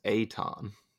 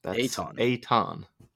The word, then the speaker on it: Eitan: Well, first of all, I did a quick Google search, and I think it's pronounced Eitan. – Eitan. E-I-T-A-N.